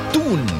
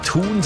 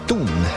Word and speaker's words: Thun: Musikus Thun. Ohne H, gell, ausgesprochen. Mhm. Nicht Thun, sondern Thun.